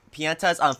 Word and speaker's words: Piantas: The Piantas? – Yes. – Did I say that right? – Mm-hmm. – Piantas, Francesca and Frankie.